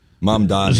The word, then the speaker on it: Mamdani: Zoran Mamdani.